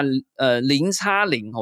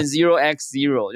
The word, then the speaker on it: 0x0: 可不可以跟我们说明一下零叉零，我现在总算知道怎么念了，我都念 0x0 就很比较长、